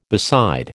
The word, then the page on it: beside: American English